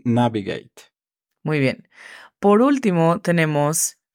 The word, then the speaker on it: navigate: Navigate significa navega. La pronunciación fonética es N, A, V, E, G, E, I, T.